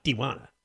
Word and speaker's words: Tijuana: If you really want to be good, you have to pronounce it like... Tijuana.